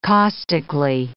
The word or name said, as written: caustically